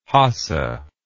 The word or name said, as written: hasa